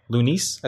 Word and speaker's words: Lunice: I'm just going to say Tonight, TNGHT, which is Hudson Mohawk and Loonies, I think is how we pronounce it, right? – Like Yuck Mouth and Numbskull, Loonies? – L-U-N-I-C-E.